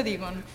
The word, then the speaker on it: Digón: ¿Se pronuncia así, Digón, como la mostaza? Fernanda. – Sí, se pronuncia Digón.